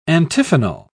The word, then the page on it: antiphonal: British English